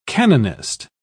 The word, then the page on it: canonist: British English